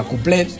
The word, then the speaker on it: couplet: Se decía couplet, como se pronuncia en francés, pero al final los españoles redujimos, quitamos esa O, quitamos la T y redujimos el género a cuplé.